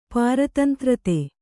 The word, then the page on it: ಪಾರತಂತ್ರತೆ: Kannada